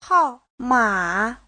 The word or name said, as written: hao4ma3